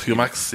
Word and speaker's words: Jumex: I mean, it is Spanish, so it'd probably be Jumex. – Jumex, yeah. It's probably a soft J.